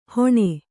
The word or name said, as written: ಹೊಣೆ